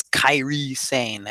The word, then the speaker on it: Kairi Sane: Niven, Kari Hoyo or Kari Sane, Sane, Kari Sane. They pronounce them a specific way, so I think it's Kairi Sane, as Jim Ross would say.